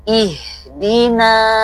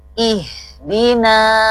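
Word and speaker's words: ihdina (اهدنا): Maka hamzah wasol atau alif yang tidak punya tanda baca dibaca i. Jadi prakteknya ihdina ya. Sekali lagi ya, ihdina.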